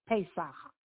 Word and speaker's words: Pesach: It is called Pesach. I'm very proud that you did it right.